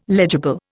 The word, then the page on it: legible: Indian